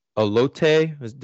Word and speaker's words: elote: So with that in mind, what I said about the cheese, the elote, did I get the pronunciation right this time? – I think Kyle's are.